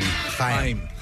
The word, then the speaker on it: Haim: Haim.